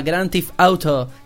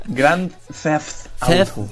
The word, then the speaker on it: Grand Theft Auto: Bueno, la última de las noticias que traemos, el título este sí que lo sé pronunciar muy bien, se llama Grand Theft Auto 5 o 5. – Pues no lo has pronunciado bien. – O 5, si quieres que lo diga. – Grand Theft Auto.